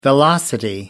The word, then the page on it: velocity: American English